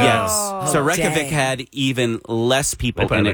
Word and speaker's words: Reykjavik: Reykjavik. – Reykjavik.